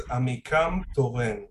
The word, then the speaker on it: Amikam Toren: No, it's Amikam Toren, which means my people rise.